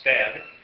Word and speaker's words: Stade: And I should mention, there was a guy in the Circle City area named Henry Stead, spelled S-T-A-D-E, but pronounced Stead.